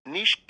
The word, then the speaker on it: niche: There are two common pronunciation variants, both of which are currently considered correct, niche and niche. – Okay. – Niche is the more common one and the older of the two pronunciations.